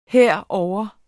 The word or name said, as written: herovre